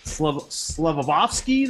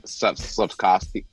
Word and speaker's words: Slavkovsky: But Montreal picking that Slavovsky there, I guess. I can't even pronounce his name. – Slavkovsky.